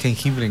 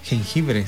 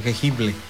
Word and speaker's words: jengibre: Todos podemos tener jengibre en casa. Ahí en una macetita, en un balde, ahí, órale. Ahí puede usted tener su propio jengibre. Jengibre, se dice, se escribe. Jengibre, no jengible, jengibre.